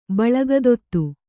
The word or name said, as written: ಬಳಗದೊತ್ತು